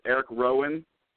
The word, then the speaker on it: Rowan: And you said Eric Rowan over Harper, right? – So that is how you pronounce it. Eric Rowan? – Yeah.